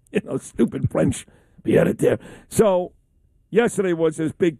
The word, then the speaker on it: pied-à-terre: Yesterday was a big day here in the city, too, talking about Mamdani for his pied-à-terre. How do you say it? – Pied-à-terre.